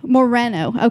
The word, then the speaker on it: Moreno: Say it correctly then. – Moreno. – Okay. See, I just want to say Morno. Every time I look at it, I'm just like, Steve Morno.